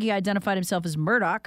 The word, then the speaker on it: Murdaugh: It's pronounced Murdaugh by locals and the family.